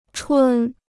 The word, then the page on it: 春: chūn